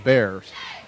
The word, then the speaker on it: Berres: His last name is spelled B-E-R-R-E-S, but Coach Brown called him Bears.